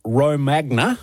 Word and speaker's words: Romagna: We tend to put the emphasis on the wrong syllable here. – Or as we would say here, Romagna.